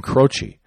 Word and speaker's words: Croce: So in the latest, part four, Pfizer senior associate Chris Croce, C-R-O-C-E, like Jim Croce, Croce, I guess it would be pronounced the same as Jim Croce, right?